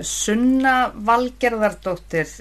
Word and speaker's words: Sunna Valgerðardóttir: Okay, my name is Sunna Valgerðardóttir. In Icelandic, most English speakers pronounce it Sunna Valgerðardóttir.